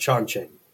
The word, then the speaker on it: Chongqing: The city's name is pronounced Chongqing.